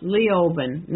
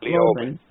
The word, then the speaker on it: Leoben: and I just wanted to point out that that city is pronounced Leoben, not Leoben.